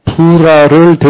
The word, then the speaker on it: 부라: 300명으로 군사의 규모를 축소하는 그날 밤 주께서 기드온에게 그의 부하 부라를 데리고 발음 잘해야 됩니다. 그의 부하 부라를 데리고 부라입니다. 미디안 군사들이 있는 막사에 접근하게 해요.